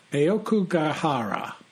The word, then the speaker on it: Aokigahara: In Japanese, and I'll do my best Japanese pronunciation, it's known as Aok, Aokigahara, also known as the Sea of Trees.